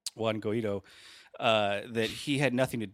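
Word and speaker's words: Guaidó: It's Guaido, but it's funnier as Guido.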